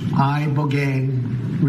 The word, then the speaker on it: Ibogaine: Ibogaine. Remember the name. Is that pronounced relatively properly, would you say? – Yes, sir.